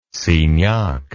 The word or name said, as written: signac